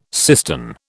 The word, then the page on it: cistern: British English